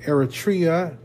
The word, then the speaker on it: Eritrea: And Eritrea is located in East Africa.